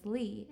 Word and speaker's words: Lea: Well, if you like the deep blue sea, the vegetable pea, the beverage tea, then you would know that my name is Lea, L-E-A.